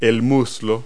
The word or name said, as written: thigh